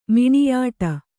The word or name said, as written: ಮಿಣಿಯಾಟ